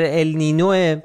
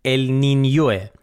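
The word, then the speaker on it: El Niño: خب عنوان این اپیزود سوپر ال نینوه، که حالا همه اول من بگم که تلفظ درستش، الان داشتیم با رضا صحبت میکردیم، تلفظ درستش ال نینیوه، نینیو، ولی خب تو رسانه‌های فارسی همه جا ال نینو نوشته میشه و احتمالاً دیگه ال نینو هم تلفظ میشه،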